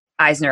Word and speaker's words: Eisner: In 2014, you became the first Black woman to win an Eisner Award. Am I saying that correctly? – Yes. – Eisner Award.